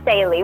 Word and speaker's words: Staley: Yes, Yelly Staley, without the N.